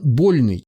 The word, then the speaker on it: Больный: Иван Больный. Ударение на первый слог.